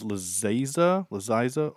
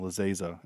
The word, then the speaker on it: Laziza: Her name is Lazaiza. Oh, I said it wrong. Lazaiza.